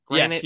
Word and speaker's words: Granit: Granit, yes, like the Rock. Well. Granit Xhaka. – It's not spelled like the Rock. – He had the most tackles in the game, Kenny. Two tackles. – G-R-A-N.